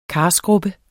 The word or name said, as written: karskrubbe